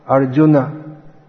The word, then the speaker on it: Arjuna: But actually, it's not a long U, but because of dropping the A at the end, it becomes pronounced as if it's along these two words. So, these words should be Guna, Arjuna, not Goon.